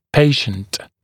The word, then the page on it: patient: ‘peɪʃnt; ‘пэйшнт